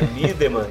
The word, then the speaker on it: Miedema: mas na verdade é Midema.